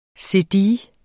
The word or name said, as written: cedille